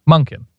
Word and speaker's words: Monken: How do you? Monken. – Like Munkin. Monken.